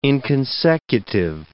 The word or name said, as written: inconsecutive